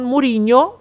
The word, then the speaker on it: Mourinho: E si complimentano con Mourinho, so che si legge così.